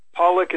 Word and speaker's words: Pawelec: And Pollack is spelled P-A-W-E-L-E-C.